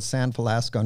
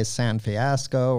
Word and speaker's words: San Felasco: People would say San Fiasco or even worse.